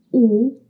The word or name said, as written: o circonflexe